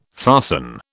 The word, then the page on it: fasten: British English